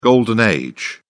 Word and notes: golden age: British English

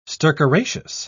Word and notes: stercoraceous: British English

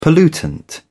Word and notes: pollutant: British English

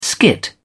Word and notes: skit: British English

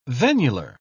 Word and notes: venular: British English

